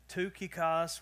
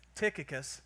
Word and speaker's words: Tychicus: It starts with a T, and you could pronounce it a lot of different ways. Tukikos was like the original translation. It's pretty difficult to say because it would get stuck in my southern accent for sure. And then Mr. T, I thought about that. All right, but I think Tychicus, and I probably butcher that a couple times because we'll use it a lot, but Tychicus is where we're going to try to land.